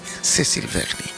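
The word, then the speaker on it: Cécile Verny: Letra de Cecil, Céline había dicho antes, Cecil Vernier, tiene un acento en la E, Cecil Vernier, y del pianista Andreas Erhinger.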